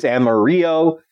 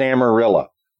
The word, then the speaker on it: Amarillo: and many are calling it the Dogman of Amarillo. And for those who think I'm mispronouncing Amarillo, the Spanish word for yellow is Amarillo, but the town in Texas is Amarillo. Why? Because America.